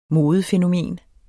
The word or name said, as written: modefænomen